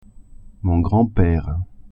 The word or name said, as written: grand-pere